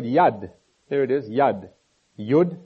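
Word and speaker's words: yod: But verse 73, you'll find J-O-O-D, or J-O-D, just above verse 73 there. And in Hebrew, you'd say yud. That's what that is, yud. And I would pronounce that J-O-O-D, but you can write it how you like. But it's not Jod, it's Yud.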